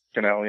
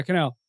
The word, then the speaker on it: Cannell: How do you ever pronounce his name? – Cannell, yeah. – Yeah, Cannell.